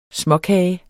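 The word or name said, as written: småkager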